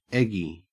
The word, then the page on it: eggy: Australian English